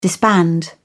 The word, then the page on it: disband: British English